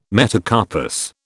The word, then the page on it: metacarpus: British English